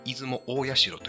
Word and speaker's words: 出雲大社: これ余談ですけど、実は本当は出雲大社と読みます。